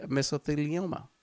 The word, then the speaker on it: mesothelioma: At the age of 72, he passed away in New York from a heart attack brought on by mesothelioma. – Remember the TV ads. Mesothelioma. – Yeah, that's how I know how to pronounce the word.